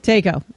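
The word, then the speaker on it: Taeko: How do we pronounce her name? – Takeo? – Yeah. It is. – Takeo. – Takeo. There we go.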